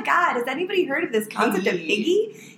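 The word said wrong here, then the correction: hygge: And then I realized, I learned the other day that it's pronounced Huga.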